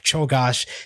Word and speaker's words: Czolgosz: Leon Czolgosz, yes, I actually already audibled this book 10 years ago, about how I know how to pronounce a strange name like Chogosh.